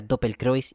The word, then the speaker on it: Doppelkreuz: Que en alemán se pronuncia Doppelkreuz y es como...